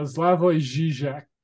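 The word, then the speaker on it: Slavoj Žižek: People like Zizek, Zizek, do you, how do you pronounce the man's name? – Uh, Slavoj Zizek.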